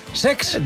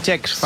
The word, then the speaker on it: Cesc: Lo he dicho mal, es Sex. – De Chex. De Hex Fàbregas, que se estrenaba en la competición.